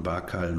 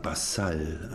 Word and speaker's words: Barcal: Auf dem C eigentlich ist ja noch so ein Akzent. Normalerweise spricht man Bakal noch ganz anders aus, wegen diesem Akzent obendrauf, sondern Basal eigentlich wird das gesprochen.